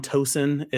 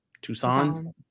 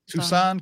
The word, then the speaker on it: Toussaint: Are we set on Tosin as how we pronounce this name? – That's how I say it. – How do they say it in the movie? Tosin? – Toussaint? – Toussaint, because he's French Haitian, so you know.